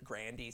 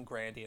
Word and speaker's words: Grande: I read a thing the other day where she was talking about how to pronounce her name, and she was like, oh, my granddad used to pronounce it Grandy, so I like the pronunciation Grandy.